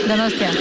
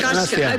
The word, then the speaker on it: Donostia: Donostia. – Donostia. – Donostia.